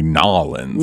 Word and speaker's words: New Orleans: New Orleans? – I think you just say Nolens.